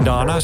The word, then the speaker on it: Madonna: Die heeft samen met Madonna, zo moet je het uitspreken, I Want You, hetzelfde I Want You van Marvin Gaye, ook gedaan.